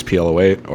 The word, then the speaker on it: PLO8: It is called PLO8 with Nate, or possibly Nate plays PLO8, or it has something to do with the fact that Nate rhymes with PLO8.